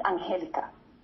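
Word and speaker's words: Angélica: And yes, my name is Angelica. It has an accent on the E, and it is extremely disrespectful.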